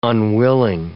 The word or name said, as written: unwilling